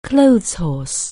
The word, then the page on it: clotheshorse: anglais